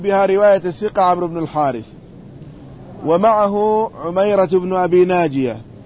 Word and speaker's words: الحارث: قال ولا يلتفت إلى زيادته، ولا تعل بها رواية الثقة عمرو بن الحارث ومعه عميرة ابن أبي ناجية الحارث، هكذا تكتب لكن تنطق الحارث، لأنه لا يعرف في الأسماء الحرث.